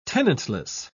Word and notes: tenantless: British English

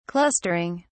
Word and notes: clustering: British English

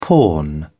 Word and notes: pawn: British English

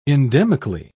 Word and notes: endemically: British English

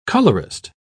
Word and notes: colorist: British English

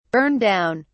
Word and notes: burn down: British English

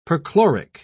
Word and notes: perchloric: British English

